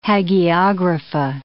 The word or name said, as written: Hagiographa